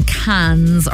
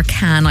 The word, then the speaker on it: Cannes: Now, you might have spotted the headlines that the film festival is going on in Cannes, or Cannes, I suppose, as the French would pronounce it, right across this week.